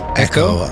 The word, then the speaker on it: Echo: How do you pronounce this label's name? Iku? – Echo.